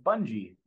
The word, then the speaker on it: Bungie: My last name is pronounced Bungie, like sort of like it looks, Devin Bungie.